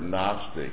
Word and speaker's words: Gnostic: Now, children, that word Gnostic is spelled Gnostic. G-N-O-S-T-I-C. The G is silent.